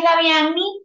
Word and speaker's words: Davianny: Oye, bueno, eh, mi nombre es Daviani, como bien dijo Daniel, pero todo el mundo me dice Davi porque es más fácil de pronunciar.